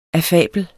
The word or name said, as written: affabel